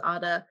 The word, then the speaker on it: Ada: Ada, or I guess the correct pronunciation is Ada and Limon.